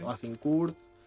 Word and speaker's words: Agincourt: Agincourt.